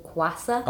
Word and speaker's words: Kwassa: What's one that I've been loving at the moment and I think he's doing a great job is I think you pronounce it Kwassa. – Oh, yeah. – A K-W-A-S-S-A.